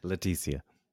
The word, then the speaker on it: Laetitia: Laetitia.